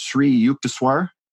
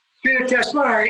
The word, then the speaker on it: Sri Yukteswar: And your teacher, it's pronounced Sri Yukteswar. Is that correct? – Sri Yukteswar is the guru, the teacher of yoga.